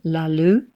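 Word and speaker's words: Lá Lugh: Now, Ethna was in the band La Lu with her husband, Gerry O'Connor.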